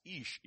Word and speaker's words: Ish: So you have Ish. It's pronounced as Ish. Ish is man.